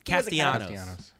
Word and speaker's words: Castellanos: Castellanos.